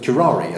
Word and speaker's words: curare: he carried around a lump of curare, I think I've said that correctly. – Yes.